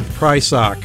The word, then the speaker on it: Prysock: Yeah, that was the name of the song, and that was Red Prysock.